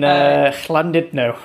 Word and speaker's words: Llandudno: In Llandudno, I think you pronounce it.